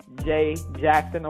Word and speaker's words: Jay Jackson: Folks, you guys have been listening to our sister, Jay Jackson.